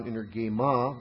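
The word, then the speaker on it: Energeima (ἐνέργημα): We have another noun, energeima, very closely related to energeia. Energeima. E-N-E-R-G, long E-M-A.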